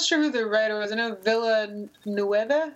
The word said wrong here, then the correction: Villeneuve: Villeneuve. Denis Villeneuve.